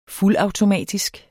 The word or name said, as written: fuldautomatisk